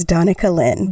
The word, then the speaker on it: Donica Lynn: It is Donica Lynn. – Do people try different variations? – I have actually been called Danica, like the car driver.